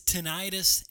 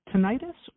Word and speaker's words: tinnitus: And also, tell us, what is tinnitus and how is this treated? – So tinnitus or tinnitus, either pronunciation is perfectly fine, is a ringing in the ears.